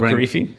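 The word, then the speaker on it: Korifi: So have you had any conversations about like the, how do you say it? The Corfi, the running. – Oh, Corfi.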